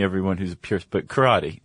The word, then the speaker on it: karate: Because this is going to be a nice overview and a nice introduction, but please do not think we are karate experts or masters, because we are not. – Let's begin. – Yes. – So, Chuck, it turns out, and you're pronouncing it correctly, karate.